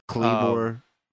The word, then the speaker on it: Kleavor: This Kleavor, is that his name, Kleavor? – Kleavor.